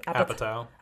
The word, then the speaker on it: Apatow: Apatow.